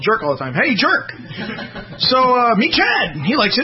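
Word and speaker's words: Tjaurk: It's T-J-A-U-R-K. But if you say his name in English, it comes out like jerk.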